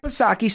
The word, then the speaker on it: Psaki: Now, of course, if that weren't enough, from Jen Circle Back Psaki, Silent P,